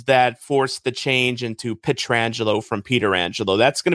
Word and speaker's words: Pietrangelo: he'll tell you it's Alex Petrangelo."